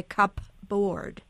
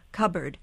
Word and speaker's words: cupboard: We don't say cupboard. We've elided it to cupboard. The stronger consonant sound takes over and obliterates the other sound.